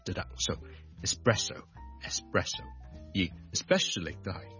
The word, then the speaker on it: espresso: 第二個字呢都是 ES 開頭的，就是 ESPRESSO espresso espresso espresso，即是意式的特濃咖啡。我們這兩個字的 ES 呢，我們都不要將它讀成 X，不要 expresso，不要 especially， but espresso， e 或者 e 都得，直接加个 S，中间唔好加咗个 K 音，